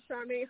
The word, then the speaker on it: Charmaine: My name is Charmaine Holland, and you can find me at my website at Charmaine Holland. I'll spell that C-H-A-R-M-A-I-N-E, Charmaine.